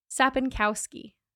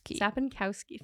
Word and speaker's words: Sapinkowski: Sapinkowski. Sapinkowski family from Chapel Hill, North Carolina.